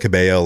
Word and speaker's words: Cabello: Oh, and I love Camila Cabello, Liar. Have you heard that song? – I do love Camila Cabello. – Oh, I love Camila. Camila Cabello.